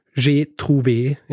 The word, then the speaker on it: G Trouvé: Sein Markenname, der ab jetzt auf allen Gerätschaften eingraviert sein wird, lautet G Trouvé, das französisch ausgesprochen J'ai trouvé ist.